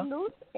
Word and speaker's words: Luz: My name is Luz. I'm calling from Florida. – Say your name again, for me. – Yeah. – Luz, L-U-Z. – Oh, Luz, like from Florida. In Spanish? Luz? – You got it.